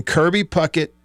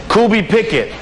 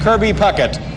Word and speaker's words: Kirby Puckett: Number 10, Kirby Pickett.